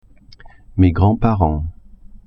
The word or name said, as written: grands-parents